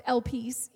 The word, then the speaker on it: elpis: And in the Greek, that word hope, it's pronounced elpis, even though it's not really peace, but that's how it's pronounced in the Greek, elpis.